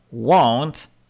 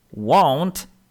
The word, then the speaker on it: won't: Inny do tego bardzo podobny wyraz w wymowie to chcieć, to want, want, want. I want to get it. Chcę to dostać. I want to get it. Nie pomylcie więc. Won't, want, won't, want.